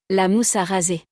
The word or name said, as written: La mousse a raser